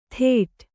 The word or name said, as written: ಥೇಟ್